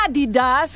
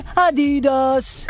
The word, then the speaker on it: Adidas: Gli americani pronunciano Adidas in modo sbagliato. – It's Adidas. – Dovrebbe essere Adidas con l'accento leggermente sulla prima sillaba e non Adidas.